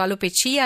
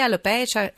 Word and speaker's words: alopecia: Senta, intanto intendiamoci subito sull'accento, alopecia, alopecia, ho detto bene? – Si può dire in entrambi i modi.